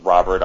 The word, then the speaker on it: Robert: The last name is pronounced Robert.